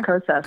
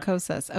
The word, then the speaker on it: Kosas: Okay, so the second one I wanted to talk about, I don't know if I'm saying this right, Kosas Reveal Concealer? – Yeah, Kosas. – Kosas, okay.